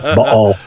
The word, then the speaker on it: Baal: There are Canaanite myths that place the activities of Baal, I only say it that way so scholars know that I know that that's how you're supposed to pronounce it, Baal.